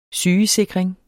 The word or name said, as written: sygesikring